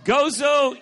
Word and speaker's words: Gozo: Gozo. – Did I say that right? – Yes.